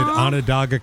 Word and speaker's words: Onondaga: Is it Onondaga County? – Yeah. – Okay. Bigfoot researchers trying to identify a strange figure photographed near a remote pond in Onondaga County, New York.